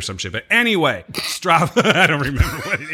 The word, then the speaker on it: Strava: He's a fan of, I believe it's Strata. Is that right? Strata? Am I saying that correctly? – Strava.